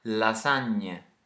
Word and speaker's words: lasagne: Can you hear the gn sound in lasagne? Let's get the native Italian to pronounce it. – Lasagne. – That is our smiley N, the gn. It's similar to an N in English,